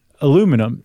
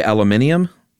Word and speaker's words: aluminium: But we spell it aluminum in the U.S. and in Canada. Apparently, the rest of the English-speaking world spells it aluminium. There's that extra syllable spelled out.